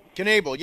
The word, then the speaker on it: Knebel: Is it Knebel? – Knebel, yeah.